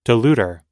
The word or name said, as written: diluter